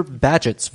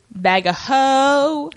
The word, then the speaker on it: Bagehot: Like, yeah, it's spelled B-A-G-E-H-O-T, but bag England, everybody, what are you going to do? – Bag a hoe.